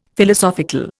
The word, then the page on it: Philosophical: Indian